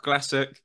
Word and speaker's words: Glasic: I love coming on these podcasts, and I love talking to American people because it's a joy to hear how they pronounce my surname. But yeah, I am Michael Glasic.